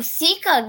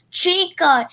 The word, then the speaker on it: Chica: Seeker loves pizza. She literally killed a robot. – Seeker? – Chica.